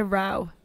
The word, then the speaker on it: Rho: Kappa Delta Rho. – Wow. That was right. I thought you were going to fuck up the Rho because it's spelled R-H-O.